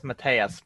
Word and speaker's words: Matias: It's Matthias, but if you pronounce it wrong, it's okay.